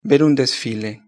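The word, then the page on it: ver un desfile: Spain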